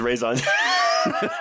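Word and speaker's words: raison d'être: Yeah, but I feel like that's always been Nicole Kidman's raison d'etre, right? Like, Did I say that right? – No, it's no. Raison d'etre.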